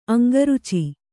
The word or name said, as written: ಅಂಗರುಚಿ